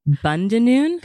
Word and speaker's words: Bundanoon: Bundanoon? – Got it.